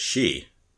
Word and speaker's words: Xi: But Chinese romanticization romanticization has XI being pronounced as she, which is just like Xi Jinping.